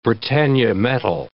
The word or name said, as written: Britannia metal